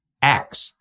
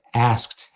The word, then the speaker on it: ask: Another word was A-S-K, axe. I used to say axe. He axed him. It's not axed. It's asked him.